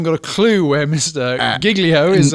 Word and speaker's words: Giglio: You are wanting to refer, I'm going to say Giglio, I think would be a more accurate pronunciation, but his actual name is... – It's accurate in your mind, and I understand you're European, but his name is Louis Giglio. – Giglio, okay.